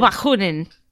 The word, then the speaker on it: Bakunin: No, Mikkel Bakunin, I think is how it's pronounced. – Hang on. Bakunin. Mikhail Bakunin. I don't know. – There we go. No, that's probably accurate.